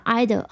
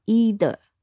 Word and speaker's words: either: 那么 either 还有一种读音叫 either。 either 或者是 either，啊，这两种都是被接受的读音。